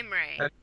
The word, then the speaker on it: Imre: How do you pronounce it? Imre? Imir? What? – Imre. – Imre, that's how I pronounce it too.